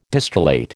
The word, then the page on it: distillate: American English